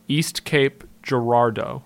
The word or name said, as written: East Cape Girardeau